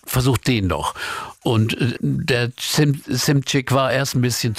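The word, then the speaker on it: Szymczyk: Joe Walsh sagt Simcik. – Simcik, ja.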